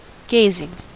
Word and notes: gazing: American English

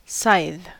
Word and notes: scythe: American English

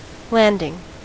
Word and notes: landing: American English

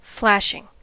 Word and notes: flashing: American English

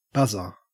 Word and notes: buzzer: Australian English

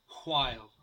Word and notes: while: American English

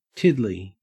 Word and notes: tiddly: Australian English

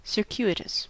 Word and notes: circuitous: American English

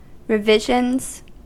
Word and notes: revisions: American English

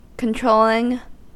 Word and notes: controlling: American English

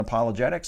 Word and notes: apologetics: American English

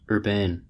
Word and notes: urbane: American English

